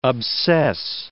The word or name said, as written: obsess